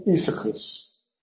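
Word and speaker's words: Aeacus: Se pronuncia Isaacus. Sí, esa es difícil. Isaacus es, o sea, acus es un yambo y to I es otro yambo.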